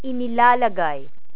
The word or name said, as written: inilalagay